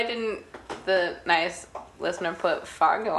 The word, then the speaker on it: Fargo: Fargo. You have to say it like that.